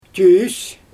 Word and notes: tyys: Xyzyl

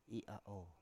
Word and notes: i7a7o7: Biak

